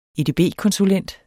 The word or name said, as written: edb-konsulent